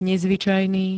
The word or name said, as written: nezvyčajný